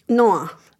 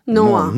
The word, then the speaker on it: Noah: Nasıl okunuyor bu? Noah Calhoun. – Noah. – Noah. Nuh yani. Ya Nuh evet Noah.